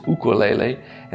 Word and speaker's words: ukulele: And the Hawaiian, the indigenous name of it is ukulele. And so I know enough from singing Hawaiian songs that if you see a U, it is pronounced oo.